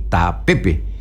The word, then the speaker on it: tapepe: Y va a tener que ponerle acento en la E, tapepe. Porque si usted dice tapepe y le pone mal el acento, puede escribir otra cosa.